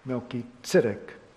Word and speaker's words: Melchizedek: I noticed, by the way, that in this Bible, pulpit Bible, and I guess it's New King James, tells us how we ought more appropriately to be pronouncing Melchizedek's name, which is Melchizedek. And that's indicated here in the translation, so maybe I should pronounce it that way too.